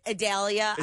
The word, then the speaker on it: Idalia: You know, Idalia? – I've heard it. Idalia. I've heard Idalia. Idalia. It almost sounds like Vidalia.